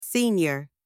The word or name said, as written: senior